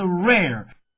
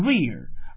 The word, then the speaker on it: rare: 吧？这店员向他推荐了剪纸，因为这个剪纸还便于携带，是吧？哎，而且呢，这外国人呢，they think that is a miracle 啊，认为它简直是个奇迹，非常奇妙。于是呢，这 Benjamin 就决定要买这个什么剪纸了，是吧？这剪纸呢，就是 paper cuts， right 啊，paper cuts。好，下面呢一块来看看咱们今天要学到的几个重点单词。那 the first one is rare， R A R E， R A R E， rare。请注意它的读音啊，不要发成 rear，而要发成 rare， air， rare，珍贵的，稀有的。在情景剧中啊，这个店员对这个 Benjamin 说了，他说呀，这个您看中的那个木雕啊， that wood carving is very rare，啊，这个您看中的这个木雕啊简直太珍贵了，是吧？